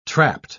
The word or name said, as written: trapped